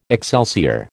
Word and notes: excelsior: American English

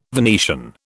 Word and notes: Venetian: British English

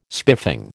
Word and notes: spiffing: American English

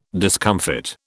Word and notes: discomfit: British English

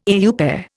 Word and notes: au pair: Indian

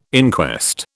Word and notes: inquest: British English

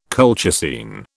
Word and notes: colchicine: British English

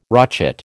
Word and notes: rochet: American English

